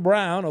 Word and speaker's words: Braun: So the Nuggets' Christian Brown, although it's spelled Braun, became the first Western Conference rookie with 15 points in a finals game since me.